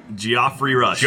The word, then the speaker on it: Geoffrey: Is that not how it's said? – Geoffrey, motherfucker! – It's spelled the same way as Jeff's name. – Geoffrey. – No, it can be the same. – Geoffrey Rush.